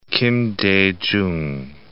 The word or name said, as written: Kim Dae Jung